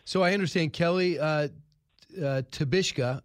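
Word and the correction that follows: Tshibaka: if I mispronounced that. Tabaka. – Tabaka.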